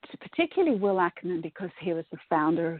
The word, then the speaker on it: Ackerman: is it Ackerman? Did I say that right? – Yes. Yes. – Good. – Yes. Will Ackerman.